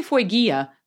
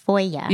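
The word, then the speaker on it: Fueguia: Oh, I say Foygia, but yes. How do you say it? – I asked them to clarify for me because I was like, Foywawa? Like, it's Foya. – Yeah. – It's easier Foya.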